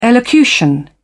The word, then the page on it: elocution: British English